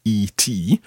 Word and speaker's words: et: Et, it's actually spelled E-T, but it's pronounced et.